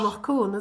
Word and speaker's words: Anach Cuain: Anach Cuain, is it? – Yeah.